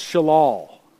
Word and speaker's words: shalal: This Hebrew term for gain, chapter in verse 11, it's pronounced shalal.